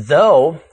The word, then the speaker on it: though: The O-U-G-H in though, the word in today's text, rhymes with toe. – Add a T to the end of though for thought, and the O-U-G-H sequence now rhymes with awe.